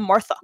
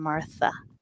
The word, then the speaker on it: Martha: when people try to say my name without the H, it's very harsh. It's Marta. So I don't like it. Okay. because, because, there's an H there, in, and even though in Spanish it's silent, I always say I'm Martha.